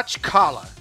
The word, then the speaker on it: T'Challa: Eu sei pronunciar T'Challa. – T'Challa.